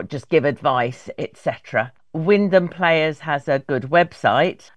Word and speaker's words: Wymondham: So it's written Y Mondham, W-Y, M for mother, O, N for nowhere, D for delta, H-A-M. So it looks like it's Wymondham, but it's pronounced Wyndham.